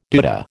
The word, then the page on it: doodah: American English